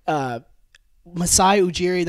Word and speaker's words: Masai Ujiri: And I was listening to Kevin O'Connor on The Mismatch, and he was saying that Masai Ujiri, that's how you pronounce his name, he's just a genius because he's let all these reports out about OG, about Pascal.